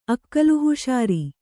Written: ಅಕ್ಕಲಹುಷಾರಿ

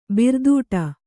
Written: ಭಾರದಡಿ

